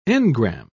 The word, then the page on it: engram: British English